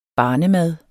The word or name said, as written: barnemad